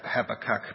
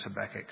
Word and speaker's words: Habakkuk: I was taught to say Habakkuk, but apparently it's really pronounced Habakkuk, so I'm trying to say it properly.